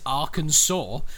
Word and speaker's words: Arkansas: Call it Kansas City, but it's Arkansas. What the fuck is wrong with you? It's spelt the same, you fannies.